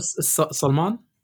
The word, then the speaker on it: Salman: You've never heard of Salman? – Never heard of him.